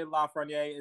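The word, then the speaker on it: Lafrenière: Alexis Lafreniere. I hope I got his name right, but Lafreniere. Alexei Lafreniere. – Lots of silent letters. Yeah, Quebec native, I think. Lots of silent letters in there.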